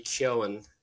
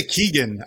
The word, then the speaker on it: Keoghan: Kerry Condren and Barry Keoghan. Keoghan is the appropriate pronunciation of the name. – Is that how you say it? Keoghan? Yeah. – Now, even he's like myself. – I say Keegan. I would always say Keegan or Keoghan.